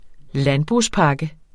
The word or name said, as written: landbrugspakke